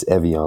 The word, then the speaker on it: Evian: Yes, Evian, like the water.